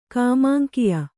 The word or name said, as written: ಕಾಮಾಂಕಿಯ